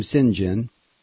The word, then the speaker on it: St. John: He pronounced his name Sinjin.